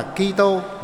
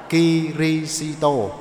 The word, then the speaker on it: Kirisito: Bây giờ chúng ta đọc là Kitô, bởi vì chúng ta đọc trực tiếp từ tiếng Latin hay tiếng Pháp. Nhưng mà ngày xưa thì chúng ta đọc qua tiếng Trung Quốc. Vì thế mà chúng ta thay vì đọc Kitô thì chúng ta đọc là Kirisito.